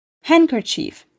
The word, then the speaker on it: handkerchief: D harfi bir kelimenin ortasında ND kombinasyonunda olduğunda da telaffuz edilmez. Handkerchief, handsome, sandwich örneklerinde olduğu gibi.